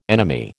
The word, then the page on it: enemy: American English